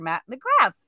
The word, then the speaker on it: Matt McGrath: If I was a good, Irish boy, I'd say Matt McGraw. That's like the correct way of pronouncing it, I guess. – Yeah. – But no, I use the, bastardized North American version of McGrath.